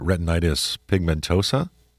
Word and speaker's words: retinitis pigmentosa: Tuesday has a genetic disease, if I'm pronouncing this right, retinitis pigmentosa.